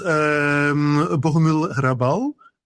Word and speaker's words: Bohumil Hrabal: Well, for instance, Bohumil Hrabal, I like a lot. Do I pronounce it correctly? Or? – Very good pronunciation.